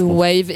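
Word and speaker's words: The Waeve: Alors W-A-E-V-E. – Ok. – Donc c'est bien prononcé, monsieur? – Bon, c'est un mot inventé, donc ça se prononce comme tu veux. – The Wave.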